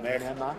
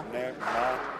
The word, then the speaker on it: Nemanja: M E H is an A, right? – Yeah, sure. – Yeah. – Ma, M-A-H. Ma. – So Nehma? – Nehma. Nya would be N-Y-A.